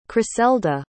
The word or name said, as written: chriselda